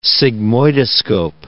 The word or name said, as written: sigmoidoscope